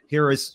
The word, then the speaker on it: Hera: Hera, yeah, Hera.